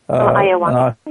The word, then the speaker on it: ayahuasca: Ayahuasca, is it? – Ayahuasca, yeah.